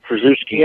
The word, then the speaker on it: Krzyzewski: Now, this will just show you about this is how it's spelled, S-C-Z-Y-M-A-N-S-K-Y, but it's pronounced Szymanski. And, like Mike Krzyzewski is not spelled anything like you think. – Krzyzewski, yeah.